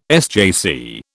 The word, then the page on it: SJC: British English